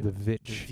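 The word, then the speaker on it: The VVitch: It's spelt stylistically with two V's instead of a W. – The Witch.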